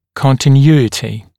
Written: continuity

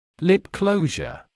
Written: lip closure

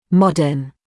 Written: modern

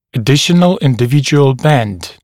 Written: additional individual bend

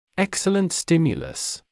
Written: excellent stimulus

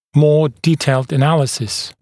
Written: more detailed analysis

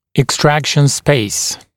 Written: extraction space

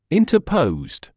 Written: interposed

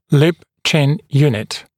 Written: lip-chin unit

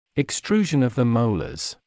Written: extrusion of the molars

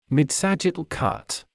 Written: midsagittal cut

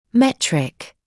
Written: metric

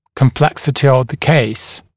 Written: complexity of the case

